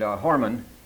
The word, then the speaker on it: hormon: Now, the word pheromone comes from the Greek pherein, pherein, which means to carry, and hormon rhymes with Norman, which means to excite or to stimulate.